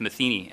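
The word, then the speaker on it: Matheny: It's spelled differently, but it's Metheny, and it's French in origin from what I'm told.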